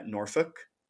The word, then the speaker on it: Norfolk: That was another like not super challenging one, but I just wanted to say it correctly because you know, with us, we would say like Norfolk. – Yeah, yeah, yeah. It's like the pronunciation of the L.